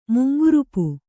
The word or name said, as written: ಮುಂಗುರುಪು